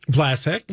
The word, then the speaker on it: Vlasic: Vlasic.